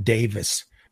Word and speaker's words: Davies: It's spelled like Davies, but I think he actually pronounces it Davis.